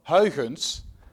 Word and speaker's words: Huygens: Actually, interestingly, on page 8 and 9, he describes the work of Huygens, that's the correct pronunciation, but Alan did, I must say, Alan was the best I've ever heard.